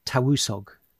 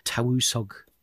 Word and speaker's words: Tywysog: So these leaders in Welsh, it's spelt T-Y-W-Y-S-O-G, in the single, and then I think the plural, there's an I-O-N on the end. My Welsh pronunciation is not good, so apologies if I get it wrong, but I think the word for these leaders was Tawusog in the single and Tawusogion in the plural, which in the sort of official documents of the time was translated into Latin as Principes, which becomes anglicised as Prince.